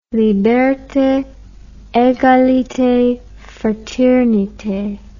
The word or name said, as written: liberte egalite fraternite